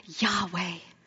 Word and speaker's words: Yahweh: And the people were so afraid of taking it wrong, saying it wrong, using it in vain, so they would shorten it to YHWH. And that's actually pronounced Yahweh, which means Adonai, Lord.